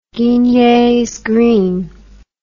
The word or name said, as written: Guignet's green